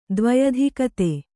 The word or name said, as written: ದ್ವ್ಯಧಿಕತೆ